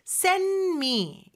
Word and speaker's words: send: 특히나 여기에서 send의 D는요. 자음 소리 사이에 꼈어요. 이럴 때는 더더욱이나 생략이 되거든요. 그래서 send me 이렇게 발음이 되고요.